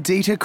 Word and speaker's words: Dieter: J-A-C-K-M-O-O-R-E. – 2. – Good afternoon. I'm Dieter Quinn. – How do you spell that? – D-I-E-T-E-R-Q-U-I-N-N.